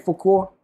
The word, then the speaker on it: Fauquier: Maybe they might hit the road and go to Fauquier or Manassas or say it right. – How you say it? Fauquier. – Fauquier.